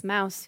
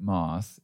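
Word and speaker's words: Mauss: She spells her name the same way that I do, and I pronounce it Moss, and she pronounces it Mouse, and I think she is correct. she's from Germany.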